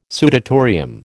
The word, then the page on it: sudatorium: American English